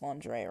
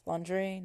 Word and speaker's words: lingerie: Lingerie. Oh, I just did it. Oh, that's so bad. I pronounced lingerie wrong. It's lingerie, not lingerie. Ha ha ha.